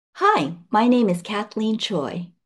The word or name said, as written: Kathleen Choi